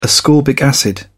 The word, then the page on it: ascorbic acid: British English